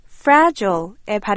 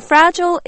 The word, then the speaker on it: fragile: 오늘은 깨지기 쉬운, 부서지기 쉬운 이란 뜻의 fragile의 발음을 공부해 보겠습니다. You know, fragile is an adjective which means easily broken or damaged, very delicate. It's not pronounced fragile. Instead, it's pronounced fragile.